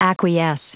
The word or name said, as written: acquiesce